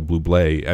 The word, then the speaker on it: Bublé: Bublé. I mean, that doesn't happen, right? Did I say his name wrong? – No, you're good.